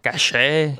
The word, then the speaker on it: caché: If you properly pronounce it, you'll say caché, and it's going to sound as if it's spelled with an SH, not a CH.